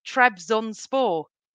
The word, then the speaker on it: Trabzonspor: And Forest and West Brom, and I never say this right, Trabzonspor. – Bang on.